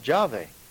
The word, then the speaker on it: Jahwe: Always before, the scholars have pronounced it Jahwe.